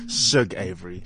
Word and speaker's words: Shug Avery: She plays Shug Avery. How do you say it? Come and see it. – Shug Avery.